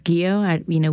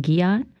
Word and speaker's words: Gio: And it's, I didn't realize that I had been mispronouncing my own last name until it's Gio. We'd been saying Giot for the longest because I think that was, I guess, the Americanized version of it.